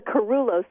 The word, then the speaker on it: Carullo: It's the Carullo Center, by the way. – I mispronounced it, sorry. – We've been around for, it's a Greek word, no one pronounces it correctly, but it is Carullo's.